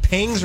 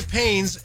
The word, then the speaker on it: pangs: Is it pangs or pains? – What is a pang? – Most people say pains, so you'll get away with it. But it really is the correct way to say that is hunger pangs. – What? – With a G.